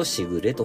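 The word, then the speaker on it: 時雨: ちょうどですね、えー、今頃から、えー、紅葉のクライマックスあたりまでですかね、えそのような時期に降る優しい雨のことをしぐれと読むわけでございます。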